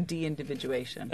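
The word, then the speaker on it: deindividuation: The term is actually de-individuation. – Okay. – So you've added extra syllables. – Yeah, that's what made it even more of a mouthful.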